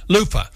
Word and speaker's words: luffa: You know, I had several people mention a month or so ago, we talked about luftas. – Right, sure. – What's how you say it? – Luffa.